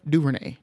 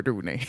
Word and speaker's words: DuVernay: Did I say her name right? Ava DuVernay. Mm-hmm. Not Ava DuVernay.